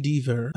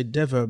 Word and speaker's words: Dever: Her name is Caitlin Dever. I was tempted to say Dever, but the pronunciation, the phonetic written here says Dever.